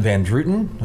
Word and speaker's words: Van Druten: Bell, Book, and Candle by John Van Druten.